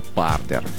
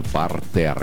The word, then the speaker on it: партер: Когда мы говорим про спорт, это партер. И вот тогда правильно. То есть пишется это одинаково, а произношение в плане ударения совершенно разное. Поэтому да, если мы говорим про театр, то это партер исключительно, а в спорте это партер.